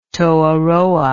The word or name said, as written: toheroa